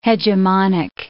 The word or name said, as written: hegemonic